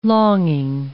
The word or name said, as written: longing